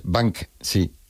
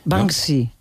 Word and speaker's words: Banksy: Y se pronuncia, en España la gente lo dice de otra manera, ¿no? Pero bueno, como Banksy. – Esas palabras. Es Banksy. – Banksy.